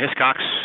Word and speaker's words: Hiscox: Well, tell us a little bit about Hiscox. Am I pronouncing it right? – Yeah, Hiscox with an X.